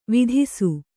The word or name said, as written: ವಿಧಿಸು